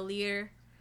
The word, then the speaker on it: Lier: Or the Lier. I can't get like the r at the end there. Not like a trill, but like just one. Leered.